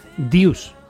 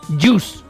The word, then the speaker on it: deuce: Y hay una puntuación en tenis que es cuando estás iguales a 40, que en el lenguaje del tenis se dice deus, que se escribe deuce. – Vale. – ¿Vale? Y nosotros decíamos jus, jus. Y claro, y automáticamente siempre decíamos jus.